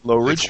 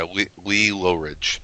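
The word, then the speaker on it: Lee Loughridge: how do you say, Lawridge? – Lowridge. – Yeah, Lee Lowridge.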